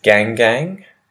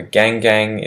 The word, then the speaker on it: gangang: The next word is gangang. So gangang is spelt G-A-N-G hyphen G-A-N-G, gangang.